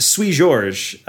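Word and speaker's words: Seu Jorge: I think it's pronounced Sui Jorge, the Brazilian singer.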